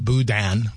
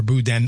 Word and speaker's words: boudin: I mean, we pronounce it boudin or boudin like D-E-H-N.